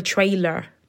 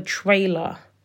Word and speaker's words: trailer: It's just, I have a weird accent. You're supposed to say a trailer. A trailer. Anyways, I always put the accent on the wrong part of the word.